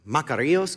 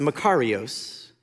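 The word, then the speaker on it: makarios: I like to call it Macarios, because it sounds like Macarena, and I start to dance. but it's actually pronounced Macarios and many will tell you it can actually be translated happy in our language.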